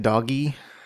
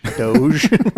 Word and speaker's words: Doge: Isn't it Doggy? – Is that? I always thought it was Doge. – Doge? – I don't know why. – It's pronounced like Rouge.